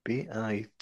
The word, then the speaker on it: vitamin: Vitamin. – Yeah. – Bitman. – No. V like Victor. – Oh, V-I-T-A. – Oh, vitamin.